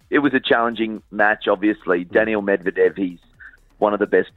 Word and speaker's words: Medvedev: Is it Medvedev? – Medvedev.